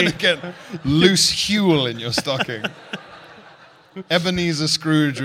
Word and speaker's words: Huel: Huel? – Yeah. Yeah. Yeah. It even rhymes with gruel.